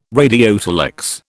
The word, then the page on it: radiotelex: British English